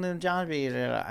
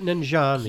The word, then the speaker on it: Nanjiani: And we forgot Kumail Nanjiani. I said his name wrong. – Yeah. – But Kumail Nanjiani.